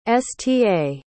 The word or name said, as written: sta